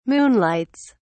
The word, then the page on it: moonlights: British English